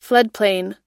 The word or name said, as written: floodplain